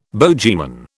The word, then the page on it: bogeyman: British English